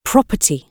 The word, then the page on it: property: British English